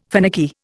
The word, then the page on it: finicky: Indian